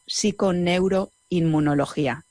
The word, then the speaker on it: psiconeuroinmunología: Está especializada en algo que es sumamente interesante y de lo que nos va a hablar en esta entrevista, psiconeuroinmunología.